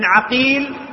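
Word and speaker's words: عَقيل: محمد ابن عقيل بفتح العين، ومحمد ابن عقيل بضم العين، فأولهما نيسابوري والثاني فريابي، وكلاهما مشهور وطبقتهما متقاربة، تقارب في الطبقة.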